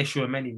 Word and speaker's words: Tchouaméni: Did I pronounce his name correctly there? – I can say Tchouaméni, man.